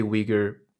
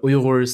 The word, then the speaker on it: Uyghur: You know, a lot of people say Uyghur, and that's kind of, for some reason, that's how the word Uyghur came to the West and has been pronounced since. But Uyghurs say Uyghur. I think one of the big things is the inability to do the R sound, and so it just translates to a flatter Uyghur.